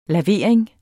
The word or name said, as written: lavering